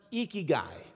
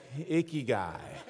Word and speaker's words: ikigai: It's pronounced ikigai. You lift your cheeks like you're smiling. It's ikigai. Say it with me. Ikigai. You got it. That's right. It's not pronounced ikigai. If I was wearing a lime green fur Speedo, that would be an ikigai. This is ikigai.